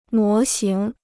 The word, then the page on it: 模型: mó xíng